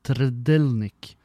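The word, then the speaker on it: Trdelník: Tredelnik heter det da. Jeg har måttet google hvordan det uttales, for det skrives T-R-D-E-L-N-I-K, Tredelnik. Så de skriver på en måte som ikke er lov i det norske ordspråket, så jeg måtte bare google det.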